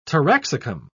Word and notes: taraxacum: British English

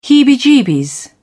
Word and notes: heebie-jeebies: British English

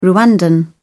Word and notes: rwandan: British English

